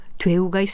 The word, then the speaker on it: 되우: 그런데 되게와 된통은 모두 첫 번째 음절을 길게 발음하는 반면에 되우는 짧게 발음한다는 점에 차이가 있습니다.